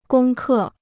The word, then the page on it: 攻克: gōng kè